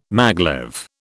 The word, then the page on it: maglev: British English